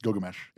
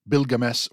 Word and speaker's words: Gilgamesh: So in other words, Gonzalo Rubio's argument is that the name was always pronounced Gilgamesh, and that readings like Bilgames or Pabilgames are modern mistakes.